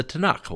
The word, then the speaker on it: Tanakh: The Tanakh, that's how they would pronounce it in Israel today. If you visited Israel, they would say their Bible is the Tanakh.